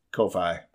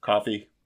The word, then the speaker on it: Ko-fi: And my big one I'm just going to start throwing out is Ko-Fi. Coffee. Ko-Fi.